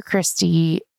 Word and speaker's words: Krysti: So at Dr. Christy, my name is spelled K-R-Y-S-T-I.